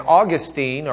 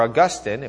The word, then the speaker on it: Augustine: And Augustine, or Augustine, if we're good Catholics pronouncing his name. I say Augustine because Protestants from Lubbock pronounce it that way.